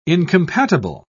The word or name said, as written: incompatible